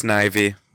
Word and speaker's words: Snivy: It's pronounced Snivy. – I nicknamed it Sneevy.